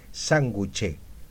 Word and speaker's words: sanguché: En Tucumán se dice sanguché. Sanguché, se dice.